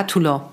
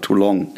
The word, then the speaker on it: Toulon: Ich habe es extra nicht gesagt, weil ich habe am Anfang immer falsch gesagt, habe ich gesagt, too long. Also viel zu lang.